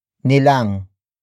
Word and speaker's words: nilang: Another example, ayaw nilang kumain. Ayaw nilang kumain. Ayaw nilang kumain. It means they don't want to eat, or they refuse to eat. Word for word, it's ayaw nilang kumain.